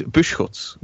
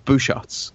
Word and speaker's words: Busschots: Okay, so we have the technical pronunciation for my surname is Buschutz, which is the Flemish pronunciation. But we have decided as a family that no one can say that in the English-speaking world. So we have opted for the alternative pronunciation of Buschutz.